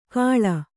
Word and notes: ಕಾಳ: kāḷa

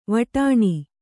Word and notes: ವಟಾಣಿ: vaṭāṇi

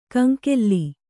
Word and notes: ಕಂಗೋಲು: Kannada

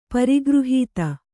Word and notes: ಪರಿಗೃಹೀತ: Kannada

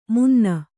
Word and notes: ಮುನ್ನ: munna

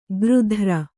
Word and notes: ಗೃಧ್ರ: gřdhra

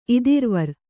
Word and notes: ಇದಿರುವರ್: idiruvar

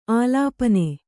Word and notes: ಆಲೇಪನ: ālēpana

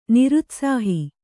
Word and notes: ನಿರುತ್ಸಾಹಿ: kannada

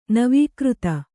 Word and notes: ನವೀಕೃತ: Kannada

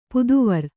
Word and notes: ಪುದುವಿರ್: puduvir

